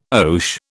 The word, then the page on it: oche: British English